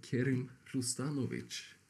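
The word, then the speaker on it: Kerim Rustanovic: Eh, Kerim Rustanovic. – Ja, precis. Tjena. U uttalade han ditt efternamn rätt? Det är helt godkänt.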